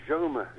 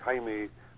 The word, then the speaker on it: Jaume: Yeah, it's Catalan. I think it's Jaume. – Jaume. Yeah, King Jaume. – And Castilian would be Jaime, and as you say, James in English.